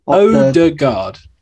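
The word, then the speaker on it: Ødegaard: Odegaard.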